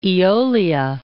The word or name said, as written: Aeolia